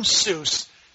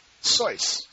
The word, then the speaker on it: Seuss: If you're calling him Seuss, he pronounced it Soyce.